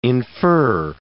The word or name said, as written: infer